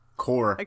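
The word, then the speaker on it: corps: Is that like a pronunciation thing? Because you totally said media corpse. – Yeah. – Core. – Okay. Is Pretty much core? Everywhere else pronounces it core. – Yeah, it's core. – I've always done it as corpse. – No, it's correctly core. – Okay. – It's silent P.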